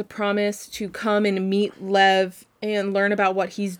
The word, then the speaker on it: Lev: Now, I'm assuming his name is pronounced Lev, because it's spelled L-E-V. – Yeah. – And I kept reading it as Levi in my head, so it's Lev.